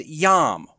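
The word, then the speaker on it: yom (יום): The first is Q-E-S, Kates, is how that's pronounced. The second is yom, Y-O-M.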